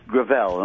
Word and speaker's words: Gravel: It's pronounced Gravel, and Gravel.